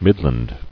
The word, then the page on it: Midland: Mid·land